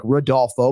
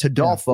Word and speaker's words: Rodolfo: name Hodolfo mm-hmm. might look to the American audience like Rodolfo, but it's Hodolfo.